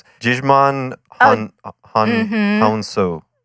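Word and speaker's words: Djimon Hounsou: Jijman Hounsou. – Yes. – Perfect pronunciation.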